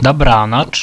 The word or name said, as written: dabranacz